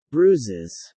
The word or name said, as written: bruises